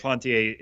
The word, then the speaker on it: Duplantier: That is how you say his name, which is awesome. – We did. – Duplantier is so fun to say.